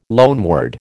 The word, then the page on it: loanword: American English